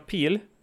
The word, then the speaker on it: Peele: Jag vet inte hur man uttalar han riktigt. Jordan Peele? Peele, ja. Kan han ju stava som det låter också.